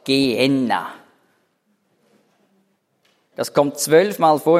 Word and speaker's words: Gehenna: Das hat die Griechen haben das ausgesprochen als Gehenna. Die Griechen können nämlich das im Wortinneren kein H sprechen. Die können also nicht Gehenna sagen, so haben sie gesagt, Gehenna.